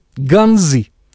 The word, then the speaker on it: Ганза: Или, как иронизируют в последнее время наши доморощенные публицисты, ганзы, с ударением на последний слог.